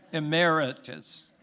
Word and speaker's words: emeritus: And then secondly, we have a special new old board member who we conferred the title Elder Emeritus. Did I say that correctly? Emeritus. I put the accent on the wrong syllable.